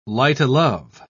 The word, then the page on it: light-o'-love: British English